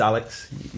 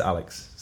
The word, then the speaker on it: ALX: But my name, it is Alex. Like, people call me Alex. – You pronounce it Alex, but it's A-L-X. – Yeah, it is Alex.